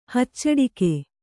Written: ಹಚ್ಚಡಿಕೆ

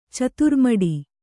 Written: ಚತುರ್ಮಡಿ

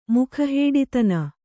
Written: ಮುಖಹೇಡಿತನ

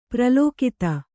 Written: ಪ್ರಲೋಕಿತ

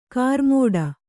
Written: ಕರ್ಮಠ

